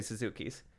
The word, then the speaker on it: Suzuki: Because me and my girls like to play Suzukis. No. – No. – Ichiro. – No. Suzuki. Rhymes with Pazuki.